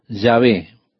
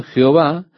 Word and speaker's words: Yahvé: El consenso general de opinión es que el nombre sería pronunciado Yahvé, pero en algún momento la pronunciación Jehová se popularizó más que Yahvé. Ahora, probablemente Yahvé es la pronunciación correcta, pero con seguridad de eso no lo sabemos.